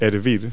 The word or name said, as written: boil